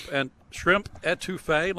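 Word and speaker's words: étouffée: Shrimp etouffee, let me pronounce that correctly. – And that's correct.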